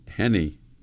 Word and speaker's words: Henny: Wasn't Hetty Youngman a lady? – No, no, Henny. Henny Youngman. Henny rhymes, rhymes with Tenny, as a matter of oh.